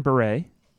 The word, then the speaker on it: Bure: No, that is how you say it, because Valerie Bure.